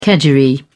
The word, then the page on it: kedgeree: British English